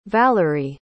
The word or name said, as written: vallerie